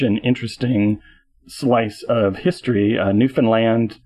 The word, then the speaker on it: Newfoundland: for indeed Newfoundland, as the locals prefer to emphasize the last syllable, is a veritable stockpot of Fortiana and Elliptony.